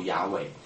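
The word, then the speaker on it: 雅威: 所以呢，你如果真的照原来读音的规则应该读雅尾，可是把 Adonai 的母音放在 YHWH 里面呢读出来呢就变成耶和华。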